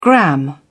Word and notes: gram: British English

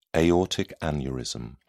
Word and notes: aortic aneurysm: British English

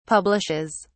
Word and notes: publishes: British English

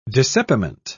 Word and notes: dissepiment: British English

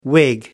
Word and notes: wig: American English

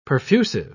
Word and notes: perfusive: British English

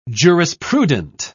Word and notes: jurisprudent: British English

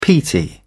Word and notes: peaty: British English

